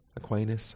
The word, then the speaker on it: Aquinas: Aquinas, okay.